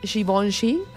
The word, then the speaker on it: Givenchy: Y dicen, ya ven, si es Givenchy, así se pronuncia.